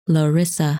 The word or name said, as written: Larissa